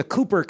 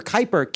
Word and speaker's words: Kuiper: The Kuiper, the Cooper, Kuiper, Q, I think it's like K-U-I-P-E-R, but I think it's pronounced Kuiper.